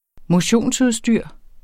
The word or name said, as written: motionsudstyr